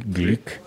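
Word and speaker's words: Glück: Luis Glick, que se pronuncia así, Glick,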